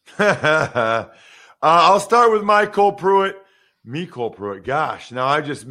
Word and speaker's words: Mecole: Mecole? How do you pronounce that? – Mecole, okay.